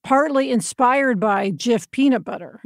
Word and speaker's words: GIF: I always thought it was GIF.